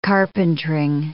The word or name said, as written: carpentering